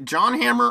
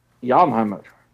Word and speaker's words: Jan Hammer: But if you go to the YouTube page of Jan Hammer, is it John Hammer or Jan? I think it's Jan Hammer. – Yes. Jan Hammer.